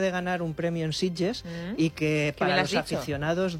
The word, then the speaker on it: Sitges: No ha dicho Sitges, ha dicho Sitges. – Lo ha dicho muy bien. – Lo ha dicho muy buena pronunciación catalana.